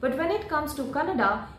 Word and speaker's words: Kannada: Karnataka film actor Kiccha Sudeep, in an interview, so he corrected the pronunciation of the interviewer who called Kannada, who said Kannada as Kannad. He said, if Hindi is not pronounced as Hind, then why should Kannada be pronounced as Kannad?